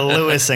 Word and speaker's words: Lewis: So everyone has now had to abandon the Lois and Clark. Did I say that right? – Yeah, let's keep it Lois and Clark. – The Lewis and Clark.